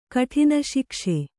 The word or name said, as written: ಕಠಿನಶಿಕ್ಷೆ